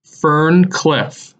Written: Ferne Clyffe

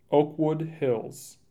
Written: Oakwood Hills

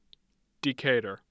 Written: Decatur

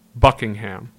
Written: Buckingham

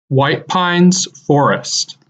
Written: White Pines Forest

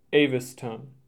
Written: Aviston

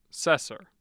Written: Sesser

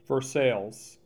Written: Versailles

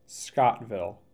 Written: Scottville

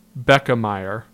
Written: Beckemeyer

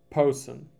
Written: Posen

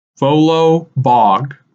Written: Volo Bog

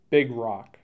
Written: Big Rock

